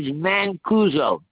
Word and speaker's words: Mancuso: My first name is Quentin, and my last name is Mancuso. – Spell it for me. – M-A-N-C-U-S-O.